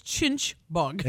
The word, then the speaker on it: chinch: the spelling Christy gave was C-H-I-N-C-H. – Yes. – Chinch bug. – It's pronunciation's a little hard. It's chinch bug. – Exactly. And you can, it's like you got a mouthful of food when you try and say chinch bugs.